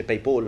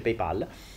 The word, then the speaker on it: PayPal: Cosa fondamentale, quando fate la donazione su PayPal, PayPal ma ho cazziato che non si dice PayPal, ma si dice PayPal,